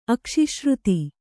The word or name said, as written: ಅಕ್ಷಿಶ್ರುತಿ